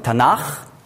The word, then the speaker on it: Tanach: Das K, wenn ein Vokal davor ist, wird weich ausgesprochen als Ch. Und wenn wir sagen Tanach, dann ist also ein A vor dem K, dann darf man nicht sagen Tanach, sondern Tanach. Ja, Kleinigkeit.